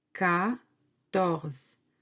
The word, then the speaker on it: quatorze: Quatorze, quatorze. This U after Q doesn't affect the pronunciation. Q-U-A se prononce K, quatorze. 15, quinze, quinze.